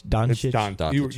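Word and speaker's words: Dončić: I think Doncic. – Doncic. You said it right.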